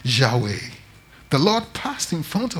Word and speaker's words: Yahweh: Today, we pronounce it as Yahweh or Jehovah.